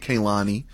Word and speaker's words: Kehlani: They say it Kehlani.